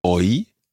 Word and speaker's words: hoy: So the first line is Navidad, Navidad, hoy es Navidad.